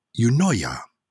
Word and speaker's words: eunoia: word I want to give you is eunoia. Mm. Uh, hmm. I pronounce it Eunoia.